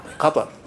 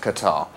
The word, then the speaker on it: Qatar: I think you'll find it's pronounced Qatar. – It's Qatar. – Qatar. – Qatar. – Qatar. – Qatar, that's the classic Arabic.